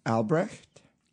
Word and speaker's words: Albrecht: How do you say that? – Albrecht? – Yeah, that's how you would say it.